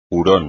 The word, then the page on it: hurón: espagnol